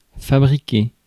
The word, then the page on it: fabriquer: [fa.bʁi.ke]; France